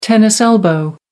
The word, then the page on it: tennis elbow: American English